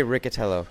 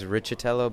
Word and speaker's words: Riccitello: We say Riccitello in the U.S., but I think the true Italian pronunciation is Riccitello, but it's kind of been Americanized, I think, in the last couple years.